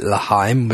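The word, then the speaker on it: L'chaim: And, you know, you listen back to this in the weeks, months to come, and you'll say, he didn't know that Laheim was a Hebrew toast. He thought it was saying lime in two syllables.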